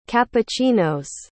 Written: cappuccinos

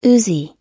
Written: uzi

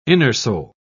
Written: innersole

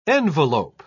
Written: envelope

